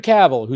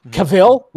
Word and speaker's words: Cavill: Cavill. We always like Henry Cavill. I called him Cavill for a long time until you found out it's Cavill.